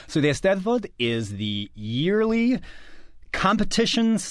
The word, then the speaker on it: Eisteddfod: Now, you, as we're talking, you just recently got back from an event in Wales, a national Eisteddfod? Am I saying that right? – You're close. Eisteddfod. – Eisteddfod. I wasn't that close.